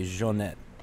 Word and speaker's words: Johnette: But do you say Johnette or do you say Johnette? – Johnette, but Johnette.